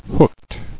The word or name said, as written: Hookedness